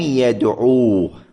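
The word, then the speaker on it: يدعوا: إذا كان إذا جاءت في آخر الفعل واو جماعة يعني كان فاعل الفعل هو واو الجماعة، هنا ألحق في الكتابة ألفا، ألف تكتب ولا تلفظ، تقول القوم لم يدعوه، القوم لم يدعوه. يدعو أنت تلفظها ماذا؟ بواو في آخرها، انتهت، يدعو، جيد، لكنك تضع بعد الواو ألفا يعني في الكتابة، في الكتابة فقط،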